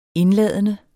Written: indladende

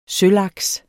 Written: sølaks

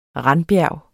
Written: randbjerg

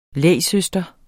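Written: LÆGSØSTRE

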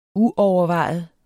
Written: uovervejet

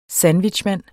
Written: SANDWICHMAND

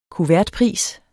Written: kuvertpris

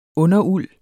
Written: underuld

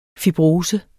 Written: fibrose

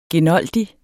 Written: genoldig

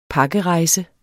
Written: pakkerejse

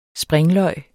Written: springløg

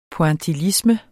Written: pointillisme

